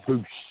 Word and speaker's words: Busch: Busch. – Busch.